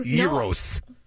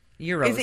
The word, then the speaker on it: gyro: No, You used to pronounce it gyros. – Gyros. – Is it gyros? – No. – I always said gyros. – Gyro. – What is it? – It's gyro. – Gyro. – Gyro. – Gyro. – J-Y-R-O, that delicious...